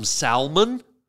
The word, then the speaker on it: salmon: Or if you're into silent letters, there's some salmon. I don't know why we need a silent letter. Why don't we just get rid of it since it's silent? Nobody uses it. Nobody says it. So why stuff it in there? How about S-A-M-O-N, salmon?